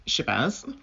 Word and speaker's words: Shabazz: My name is Shabazz. It's very easy to say. It's spelt phonetically, which is how this language is spoken.